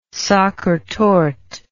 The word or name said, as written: Sachertorte